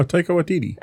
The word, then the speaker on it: Taika Waititi: Oh, Taika Waititi. – Oh, see, look, and I said it right.